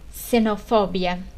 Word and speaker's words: xenofobia: Então, quando o X está no início da palavra, ele é pronunciado como se fosse uma C do português, xenofobia.